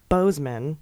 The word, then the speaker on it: Boozman: Senator Boozman is pronounced Bozeman, and moron is pronounced Moran, though I quite prefer Boozman and moron.